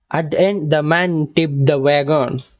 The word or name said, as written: tipped